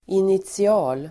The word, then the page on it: initial: [initsi'a:l]